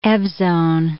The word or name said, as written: evzone